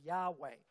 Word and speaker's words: Yahweh: But the more proper and almost definitely correct way of understanding or translating that is, or speaking that, pronouncing it, is Yahweh.